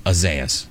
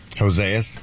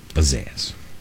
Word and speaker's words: Isaias: Isaias. – Hosaias? – Isaias.